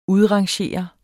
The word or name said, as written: udrangere